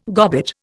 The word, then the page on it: gobbet: Indian